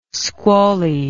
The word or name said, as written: squally